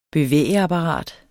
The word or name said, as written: bevægeapparat